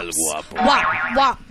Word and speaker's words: El Guapo: El Guapo. Is that how you say it? El Guapo. – El Guapo.